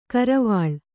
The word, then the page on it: ಕರವಾಲ: karavāla